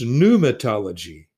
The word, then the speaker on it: pneumatology: This word begins with a P, which is not pronounced. It's pneumatology.